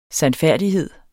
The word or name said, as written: sandfærdighed